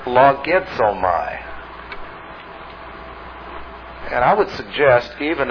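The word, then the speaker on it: Logizomai (λογίζομαι): L-O-G-I-Z. And it's, the Z is pronounced Z, like in suds. Logizomai. Logizomai.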